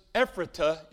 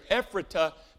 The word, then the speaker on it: Ephrata: In the days of the American Revolution, there lived in a place, Ephrata is how you pronounce it, Ephrata, Pennsylvania.